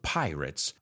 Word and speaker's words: pirate: We'll take a peek into the world of pirates on this week's episode of FYI. And I want you to pay attention to how I'm pronouncing it. Pirate. I didn't say rate. I said writ.